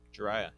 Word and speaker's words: Jiraiya: Jiraiya, yeah.